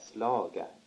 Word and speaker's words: slaga: Och säg, när de använde sån här slaga, eller ja, kallade det för slaga? – Ja, det gjorde jag. – Hur uttalades ordet? – Slaga. Och slaga, det var det uttalades.